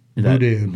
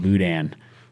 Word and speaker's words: boudin: I'll probably say the name wrong. Bodan? – Boudin. – Boudin.